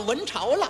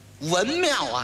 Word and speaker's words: 庙: 你看见这么一会儿，咱们到了文朝了。一指这块匾，文庙啊，给念成文朝了，念错了一个。